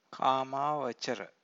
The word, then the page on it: kamavacara: Pali